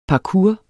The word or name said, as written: parkour